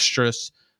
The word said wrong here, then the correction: Strus: I just said his last name wrong. Max Strus, Gabe Vincent, Duncan Robinson, Haywood Highsmith, and Caleb Martin, all undrafted.